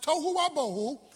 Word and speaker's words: tohu wa bohu: The Hebrew word is tohu wa bohu, without form and void. It's pronounced tohu wa bohu, and tohu wa bohu means garbage dump.